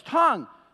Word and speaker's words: tongue: It's not tongue. It's not vague. It's vague. It's tongue. The U-E is silent.